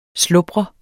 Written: slubre

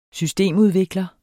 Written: systemudvikler

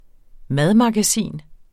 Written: madmagasin

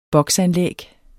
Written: box

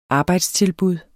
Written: arbejdstilbud

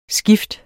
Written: skift

